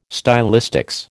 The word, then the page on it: stylistics: American English